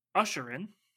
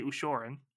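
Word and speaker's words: Ushoran: apparently it's pronounced Usherin. – Ooh. – But I like to say Ushorin.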